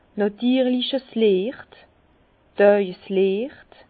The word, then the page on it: nàtirlich Liecht, Dààjesliecht: Bas-Rhin; Herrlisheim